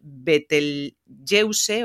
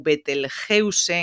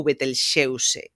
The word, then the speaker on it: Betelgeuse: a pronunciación segue sendo como ese árabe original Betelgeuse ou Betelgeuse en castelán. En galego creo que poderíamos dicir Betel Betelgeuse ou Betelxeuse. Creo que serían as dúas formas máis idóneas ou máis respectuosas, non creo que coa etimoloxía para nomear a esta estrela.